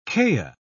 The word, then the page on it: kea: British English